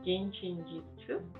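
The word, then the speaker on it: Jin Shin Jyutsu: Qué interesante, fíjate tú, te iba a preguntar también, eres practicante de Jin Chin, y me corrijas si no lo estoy pronunciando bien, Jin Chin Yuitsu. – Sí, Jin Chin Yuitsu.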